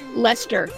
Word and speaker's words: Leicester: Leicester. It's spelled like it should be Leicester, but it's Leicester.